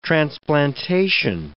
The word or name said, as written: transplantation